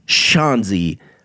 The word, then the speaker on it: Schanze: Del Shaughnessy, and it's spelled S-C-H-A-N-Z-E, Shaughnessy. – And it's Dell, as in Dell, is D-E-L-L, like the computer.